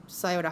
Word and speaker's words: Scioto: Scioto River is S-C-I-O-T-O. So you get Scioto. – Yeah. Scioto or Scioto. – Mm-hmm. – I mean, I went through Scioto High School, and every single time, whenever we went through to a competition or whatever, it was Scioto scores again.